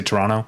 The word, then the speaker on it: Toronto: Speaking of Canadian cities, Torontonians don't pronounce the second T. – Toronto? – They just say Toronto.